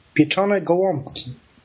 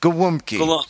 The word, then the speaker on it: gołąbki: We pronounce it as Pichanika Wumpki. Repeat after me. Pieczone. – Pieczone. – Pieczone. – Gołąbki.